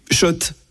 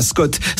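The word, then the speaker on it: Schott: Oui, Shot. Et pas Scott. Ça fait des années qu'on prononce mal la marque.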